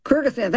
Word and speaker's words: Kyrgyzstan: Kyrgyzstan. – Kyrgyzstan, thank you.